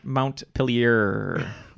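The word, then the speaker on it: Montpelier: Yeah, that's Mount Pilier. – Perfect. – Just has a couple silent letters in there.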